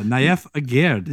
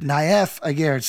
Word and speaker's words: Naif Aguerd: Yeah, Naif Aguerd. – Naif Aguerd.